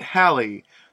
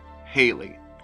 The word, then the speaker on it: Halley: But one pronunciation we can say pretty confidently is wrong is the one you, if you're an American, probably grew up with. Haley.